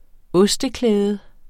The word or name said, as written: osteklæde